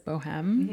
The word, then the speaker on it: Bohēme: And then, okay, so have you ever tried Bohem candles? I used to call them Bohem until I realized that's not how you pronounce it. It's Bohem.